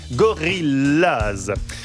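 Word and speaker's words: Gorillas: Se prononce comme en anglais, Gorillaz.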